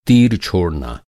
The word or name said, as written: छोड़ना